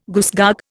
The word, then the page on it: goosegog: Indian